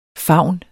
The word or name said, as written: faun